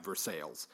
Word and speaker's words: Versailles: Here in Missouri, we pronounce Versailles, Versailles.